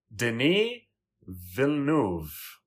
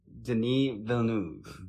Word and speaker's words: Denis Villeneuve: And his name is Denis Villeneuve. – Okay. Denis Villeneuve.